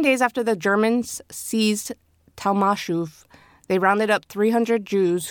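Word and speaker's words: Tomaszów: 7 days after the Germans seized Talmashof. – Say that again. – Talmashof. – Talmashof. – So it's spelled Tomaszow, but that's it's pronounced Talmashof.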